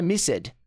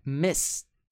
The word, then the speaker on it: missed: Gente, pronúncia não é missed, é missed. Missed.